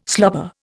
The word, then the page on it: slobber: Indian